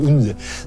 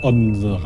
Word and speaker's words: Odense: Einzige Stadt, die ich was in Dänemark kenne, weil ich sie immer falsch ausspreche, ist Odense. Es wird Odense geschrieben. Und dann blamiert man sich immer, wenn man Odense sagt. Also Odense.